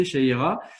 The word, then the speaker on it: Chahira: Alors, donc ton prénom, c'est Sha... pardon. Que je ça, c'est Shaira. – Que je ne l'écorche pas. Alors, enchanté, Shaira.